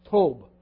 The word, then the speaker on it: Tob: Now, the Hebrew word there translated goodness, for lack of a better pronunciation, it's just kind of like TWB, whether you want to have that TUB, TOWB, but Tob.